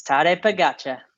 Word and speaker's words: Tadej Pogačar: Yes, Tadej Pogačar.